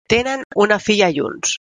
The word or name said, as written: junts